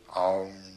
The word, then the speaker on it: AUM: It's written A-U-M. You start in the back of the mouth, ah. And then ooh, you fill the mouth. And mm closes at the mouth. And when you pronounce this properly, all vowel sounds are in that pronunciation, om.